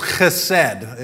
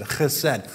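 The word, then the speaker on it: chesed (חֶסֶד): Steadfast love in the Hebrew is one word, and it's chesed, is how it's said, chesed.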